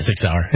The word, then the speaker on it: Sig Sauer: You know, you're sitting here with Sig Sauer. Is that how you pronounce that? – Yeah, Sig Sauer.